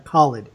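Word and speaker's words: Khaled: Or Khalid is how it's pronounced. He actually says it in the song.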